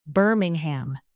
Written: birmingham

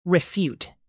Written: refute

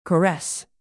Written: caress

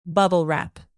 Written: bubble wrap

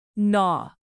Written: gnaw

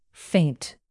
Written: feint

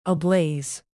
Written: ablaze

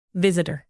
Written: visitor

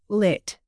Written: lit

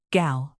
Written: gal

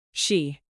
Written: she

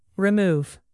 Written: remove

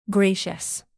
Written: gracious

